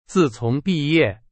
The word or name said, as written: 自从